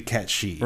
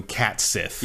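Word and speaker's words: Cait Sith: Because technically it's supposed to be Ketshi. – Right. – In Gaelic. But everyone I've ever met calls him Cat Sith.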